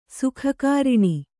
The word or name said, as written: ಸುಖಕಾರಿಣಿ